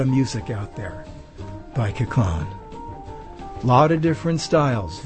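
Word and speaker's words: Koechlin: That was composed by Charles Kaclan, I believe it's pronounced. I want to say Kirkland, but it's not. It's Kaclan, I think.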